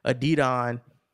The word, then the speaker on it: Adidon: None of the niggas that listen to the story of Adion or however you pronounce it, Adidon, are listening to your album.